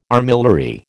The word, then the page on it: armillary: American English